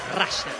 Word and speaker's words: Russell: No, pronunciación la real es Russell. – Russell. – Sí. Me lo creo. Muchos le dicen Russell. Está perfecto, pero la pronunciación correcta es Russell.